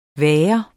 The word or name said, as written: VAGERNES